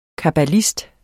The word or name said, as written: kabbalist